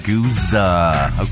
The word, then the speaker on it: Guizhou: I completely got it wrong. – Guizhou. – That doesn't, if you try and do it phonetically, that doesn't look... – Guizhou. – Okay, thank you.